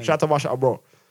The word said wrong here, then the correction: Ambrosius: All I'm saying is... – Said it wrong again.